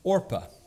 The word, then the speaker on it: Orpah: By the way, did you know that the celebrity Oprah Winfrey was originally named Orpah after this Bible character? Apparently her family started mispronouncing it, though, and it kind of stuck, and so her name became Oprah.